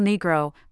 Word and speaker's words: Negro: In Argentina, El Negro, pronounced with a short E, can be a friendly way to refer to a man with darker hair or skin.